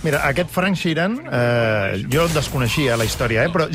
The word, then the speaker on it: Frank Sheeran: Frank Sheeran. Am I saying that right? – Yeah, you said it right.